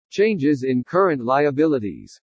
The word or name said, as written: CHANGES IN CURRENT LIABILITIES